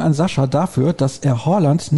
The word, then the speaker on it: Haaland: Das A ist ein O wie in Dorf. Tatsächlich wird auch das D am Ende im Norwegischen nicht ausgesprochen, aber jetzt will ich nicht zu viel erwarten. Das klingt dann auch ein bisschen komisch, wenn man sagt Erling Haaland. Also das D ist so weich, dass es quasi nicht ausgesprochen wird.